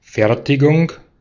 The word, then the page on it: assembly: American English; [ə.ˈsɛm.bli]; British English; /əˈsɛmb.lɪi/